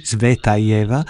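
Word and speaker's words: Tsvetaeva (Цветаева): Sì, la musica è dedicata alla poetessa russa Marina Tsvetaeva, il nome si pronuncia così, Tsvetaeva.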